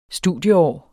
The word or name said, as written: studieår